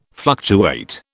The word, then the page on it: fluctuate: British English